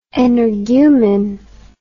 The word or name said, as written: energumen